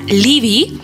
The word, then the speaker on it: Laufey: Ella, quien estamos escuchando, se llama Libby, pero se escribe Laufey, así para que la busquen en plataformas.